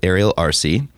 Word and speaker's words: Ariel Arce: But sitting here in Special Club with Ariel Arce.